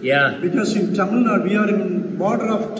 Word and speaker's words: Tamil Nadu: And we're in Tamil Nadu? Yeah. I'm, i say that right? Yeah, yeah. Go ahead and say it. Pardon me? Tamil Nadu? Tamil Nadu.